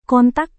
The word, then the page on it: kontak: Indonesian